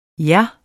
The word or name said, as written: Jer